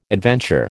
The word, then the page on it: adventure: American English